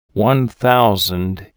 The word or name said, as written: One Thousand